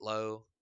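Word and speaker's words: Lowe: So Nate Lau, pretty good this year, or Nate Low. I think you can pronounce his Low.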